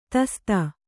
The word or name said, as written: ತಸ್ತ